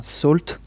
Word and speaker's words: Salt: Alors à côté, nous avons des petits nains qui sont tout à fait euh, de taille tout à fait raisonnable, comme euh, NaCl ou enfin Salt, comme, on est censé le, prononcer, ou Lipsodium.